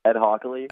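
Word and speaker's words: Ed Hochuli: How do you pronounce his name? Ed Hoochley or whatever? – Ed Hockley. – Ed Hockley, yeah.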